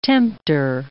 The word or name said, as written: tempter